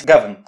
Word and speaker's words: Гавейн: Гавейн у нас Гавен. Гавен. Я постоянно себе напоминаю, что в, в, в язычном произношении ударение всегда на первый слог.